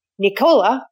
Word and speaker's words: Nicola: Okay, I'm here at Peak of the Vine with Nicola. Did I say it right? – Yes. – Like Ricola, he told me.